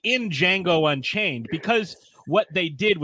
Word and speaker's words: Django: He's like, Django. The D is silent.